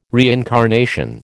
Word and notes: reincarnation: American English

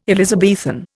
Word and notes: Elizabethan: Indian

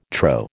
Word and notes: trow: American English

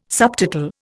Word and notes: subtotal: Indian

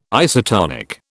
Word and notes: isotonic: British English

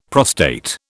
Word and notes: prostate: British English

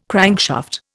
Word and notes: crankshaft: Indian

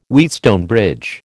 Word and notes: Wheatstone bridge: American English

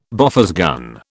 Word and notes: Bofors gun: British English

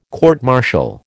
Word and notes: court martial: American English

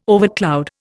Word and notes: overcloud: Indian

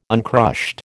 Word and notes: uncrushed: American English

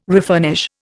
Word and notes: refurnish: Indian

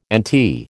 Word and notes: NT: American English

